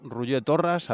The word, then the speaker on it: Roger Torras: Pues sale, vamos con Roger Torras, a ver si lo he pronunciado bien.